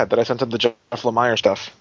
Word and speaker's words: Lemire: We did get a correction on the blog that it's pronounced Jeff Lemire.